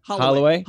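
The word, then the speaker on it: Holieway: Holy Way? Am I saying that right? – Holloway.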